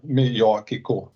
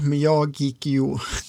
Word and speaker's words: Miyagikyo: Jag är säker på att det är korrekt uttal nu? Miyagiko. – Miyagiko, Miyagikyo. Yoichi vet jag på, den här är lite lurigare. Miyagiko brukar jag säga ja, jag litar på dig.